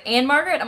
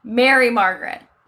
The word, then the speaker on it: Mary Margaret: People say, people are always like, Anne Margaret? I'm like, no, she was a drunk actress. Mary Margaret is my name.